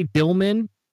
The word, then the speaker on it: Dillman: And I'm going to say Dillman because it's actually how he pronounces his name, but it also sounds funny, kind of like a dill pickle.